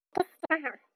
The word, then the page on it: kischikwaahwaau: Chisasibi